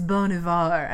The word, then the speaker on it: Bon Iver: And I was like, what, how do you pronounce it? And he was like, it's Bon Iver. And I was like, cool, so Bon Iver.